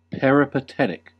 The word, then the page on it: peripatetic: American English